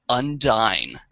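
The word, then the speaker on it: Undine: Relics, good episode, to help Tuvok negotiate a coordinated response to the Undyne. I believe that's how you pronounce that. – Yes, that is species 8472 for the uninitiated.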